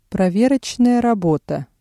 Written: проверочная работа

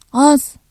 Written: az

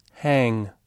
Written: hang

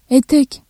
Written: etek

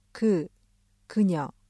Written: 그, 그녀

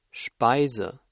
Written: Speise

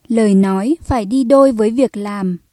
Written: lời nói

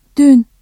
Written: dün

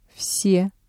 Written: все

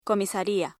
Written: comisaría

